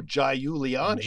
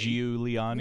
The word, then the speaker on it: Giuliani: he has an IU, but we don't go Giuliani. – Giuliani, no. – Yeah. We have the I not pronounced, which is I'm gonna go out on a limb here because this is something that bothers me.